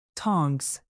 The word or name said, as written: tongs